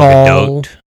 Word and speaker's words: Gadot: Her first name is pronounced like Doll, and her last name rhymes with afloat. So, oh, it's Gal Gadot.